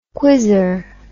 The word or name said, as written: quizzer